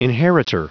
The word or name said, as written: inheritor